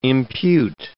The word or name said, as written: impute